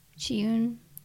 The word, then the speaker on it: Chiune: It's spelled C-H-I-U-N-E, and the last name is S-U-G-I-H-A-R-A. – Chiyun.